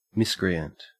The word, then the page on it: miscreant: Australian English